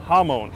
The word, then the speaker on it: Hamon: Hamon. – Yes, how do you say it? – Moan. Like ha, ha and moan.